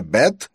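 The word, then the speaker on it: бет (בית): Этот слог можно, в свою очередь, сократить до первой буквы «б», которая произносится «бет» и представляет собой открытый квадрат со стрием в середине.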